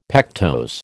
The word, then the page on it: pectose: American English